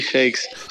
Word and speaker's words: Chaix: Yeah, it's Louis Shakes. – Chakes. So the X is a hard X.